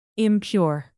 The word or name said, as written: impure